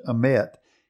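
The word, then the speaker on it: emet (אמת): Now, this word truth in the Hebrew is spelled in our English language, emet, E-M-E-T-H.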